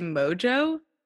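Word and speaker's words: Mongeau: It's like spelled like Montague, but I guess you're supposed to say it Mojo.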